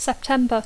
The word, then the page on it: September: English